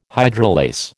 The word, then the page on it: hydrolase: American English